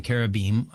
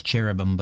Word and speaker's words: cherubim: All right, so you mentioned the seraphim and the cherubim. As you say, we often pronounce it as cherubim, but it's cherubim and seraphim.